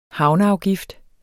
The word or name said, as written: havneafgift